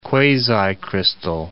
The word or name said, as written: quasicrystal